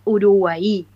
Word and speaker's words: Uruguay: Esta yerba mate es Uruguay, con acento en la última I, bien guaraní.